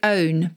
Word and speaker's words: pwn: So famously, there's a word which is pronounced like own, but it's spelt P-W-N. And it's used in the same sense as own. So to own an opponent, P-W-N, an opponent, is to completely dominate them in online play.